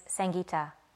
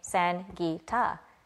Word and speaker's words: Sangeeta: And I would, you know, and even to this day, when I walk up to someone, I say my name is Sangeeta. So that they can phonetically hear me say Sangeeta. My name is Sangeeta. It's the sun, right? In the name, Sangeeta, not Sangeeta.